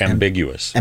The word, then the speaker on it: ambiguous: Am- ambiguous. Ambiguous. ambiguous. – amb- ambiguous. I was putting an extra syllable in there.